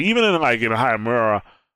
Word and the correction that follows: Hanamura: You know, I so believe it's pronounced Hanamura. Hanamura, yes. One thing for everybody that's listening to this podcast, I'm terrible with names.